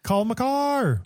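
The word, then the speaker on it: Cale Makar: That Kale, I don't know how you say it. Kale Maker, McCarr. – Cole McCarr.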